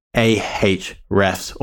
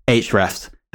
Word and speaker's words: Ahrefs: Before we jump into that, though, of course, we have to give a shout out to our insanely great sponsor, Ahrefs, or Ahrefs as the name should be pronounced.